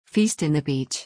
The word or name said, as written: feast in the beach